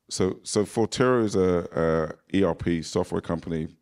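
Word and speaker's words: Forterro: So you're the CEO, correct me if I'm saying this wrong, you're the CEO of Fortenero. – Fortero, your partner in Courtin Capital, and you're chairman in FFG.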